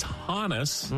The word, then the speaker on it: Hannes: Remember, it's Hannes, as in it's short for Johannes. – I apologize. – He is Swedish, Johannes. Most people say as Honus. If it's Honus, like rhymes with bonus. It's Johannes.